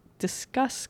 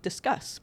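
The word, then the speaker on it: Disqus: It really looks to me like discus. Apparently discus should be pronounced discuss. So I'm going to call it discuss comments.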